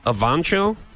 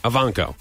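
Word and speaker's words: Evancho: Jackie Ivanko. – Ivanko.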